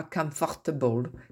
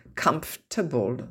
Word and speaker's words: comfortable: Breath, flower, squirrel, comfortable, hein, on ne dit pas comfortable, comfortable, donc le OR en somme ne se prononce pas.